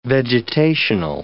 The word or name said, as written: vegetational